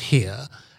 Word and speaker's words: Hir: So Felicity Huffman is going in a play called spelled H-I-R, pronounced here.